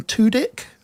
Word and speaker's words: Tudyk: And it stars the comedic actor Alan Tudyk. That's how you pronounce it.